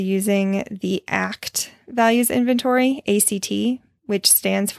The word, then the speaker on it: ACT: From Wikipedia's article on ACT, Acceptance and Commitment Therapy, ACT, typically pronounced as the word ACT, is a form of psychotherapy, as well as a branch of clinical behavior analysis.